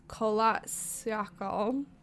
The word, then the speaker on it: Kholat Syakhl: Um, but a fierce snowstorm pushed them off their intended route and onto the slopes of a mountain called Kholat Syakhl. Great. How do you say that one? I think that was perfect. Yeah, yeah. yeah, that was great.